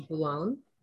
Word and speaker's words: Boulogne: So my last name is Boulogne. Um, you say it like Cologne with a B.